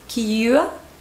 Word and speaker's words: curious: Cuidado con la pronunciación de esta. Es curious.